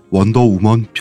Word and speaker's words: 원더우먼: 안 물어봐도 알려주는 남 얘기 63회 방송 원더우먼 편을 시작하겠습니다. 어 이게 어 발음이 막 응? 내가 원더우면이라 그랬어 아까는.